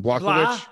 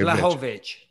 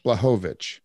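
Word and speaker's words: Błachowicz: I keep on saying Blachowicz. – Say blah. – Blah. – Ho. – Ho. – Vich. – Vich. Okay. – Blachowicz. – Blachowicz.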